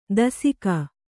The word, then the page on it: ದಸಿಕ: dasika